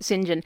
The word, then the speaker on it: Sinjon: Yeah, yeah, Saint John is yeah, but they they, they uh, pronounce it Sinjin. Um, actually, they they actually wrote it Sinjin, but um, S I N J O N.